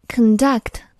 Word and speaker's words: conduct: 下个词 conduct，它做动词表示进行、组织、实施。但是它做名词的时候读音就不一样了，重音就在首字母前面，读作 conduct。